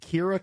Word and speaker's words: Kyra: They pronounce it Kira, K-Y-R-A.